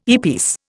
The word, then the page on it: earpiece: Indian